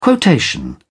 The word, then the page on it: quotation: British English